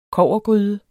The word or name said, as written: kobbergryde